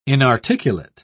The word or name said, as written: inarticulate